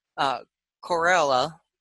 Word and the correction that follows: Corellia: am I saying that correctly? – Corellia. Corellia.